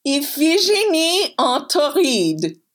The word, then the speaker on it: Iphigénie en Tauride: Iphigénie en Tauride.